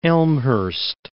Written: Elmhurst

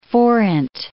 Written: forint

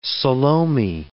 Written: Salome

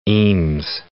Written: Eames

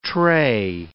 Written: tray

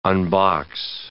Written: unbox

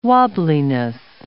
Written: wobbliness

